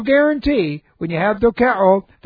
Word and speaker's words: dokeo: The Greek is dokeo. The word dokeo, if you're interested, the word dokeo looks like this, and D-O-K-E long O.